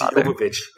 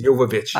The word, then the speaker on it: Jovovich: I'm so disappointed you didn't say Milla Jovovich. – I didn't want to mispronounce it. I know you just did. It's, it's Jovovich. It's Jovovich.